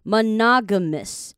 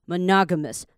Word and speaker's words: monogamous: long term 也没有什么问题，正常把它念出来 monogamous 好， mona 这都没问题， g 也没问题，是后面的 mu mous 不是 mu mous Miss 千万不要念成 mouse、哦、不是 mouse 是 mus monogamous，这样才会比较好听。